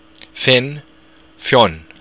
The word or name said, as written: fionn